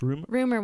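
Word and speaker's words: Rumer: Rumor Willis.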